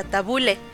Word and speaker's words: tabulé: Es que se escribe tabulé, pero se pronuncia tabule.